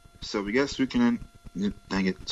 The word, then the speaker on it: Suikoden: Suikoden. Right, Suikoden.